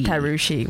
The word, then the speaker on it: Tarushi: Well, what happened was the way my Sri Lankan first name is spelt, some people pronounce it as thrushy. Yeah, it's not a very proud name. – You've got me, yeah. Darling. – Tarushi. – Tarushi. – Tarushi, which nice.